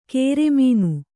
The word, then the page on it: ಕೇರೆಮೀನು: kēre mīnu